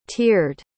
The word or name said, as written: teared